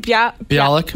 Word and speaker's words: Bialik: Bialik. – Bialik.